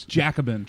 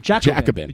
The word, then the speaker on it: Jacobin: Jacobin. – Jacobin. – If you want to be a pretentious French person, you can say Jacobin.